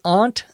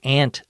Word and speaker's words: Aunt: Rachel says, "My Aunt Susan," you could also say "my Aunt Susan," either pronunciation is correct, "called, and she's coming for a visit.